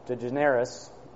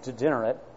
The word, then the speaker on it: DeGeneres: And right before that is the Ellen, I can never say her name, DeGeneres, or some say degenerate, her TV show.